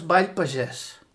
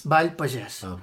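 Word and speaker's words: Ball pagès: First of all, how do you pronounce Ibithenko country dancing? – Well, nowadays, altogether, we know it as Bail Pagés. – Bail Pagés.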